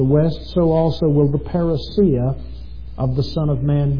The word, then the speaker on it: parousia: It is parousia. If you're taking notes, that word in its transliterated form is spelled P-A-R-O-U-S-I-A. Parousia.